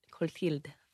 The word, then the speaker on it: Cotillard: Cotillard. – Cotillard.